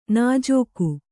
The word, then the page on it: ನುಜುಗು: Kannada